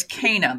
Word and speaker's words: Cana: Now you pronounce it Cana, and I've always heard it as Cana, the wedding at Cana. Is that the difference between a German and an English pronunciation? – Absolutely.